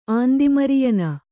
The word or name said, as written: ಆಂದಿಮರಿಯನ